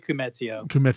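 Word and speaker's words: Koumetio: I always mispronounce his name. – it's Billy Kumetio. – Kumetio. – I always say that wrong.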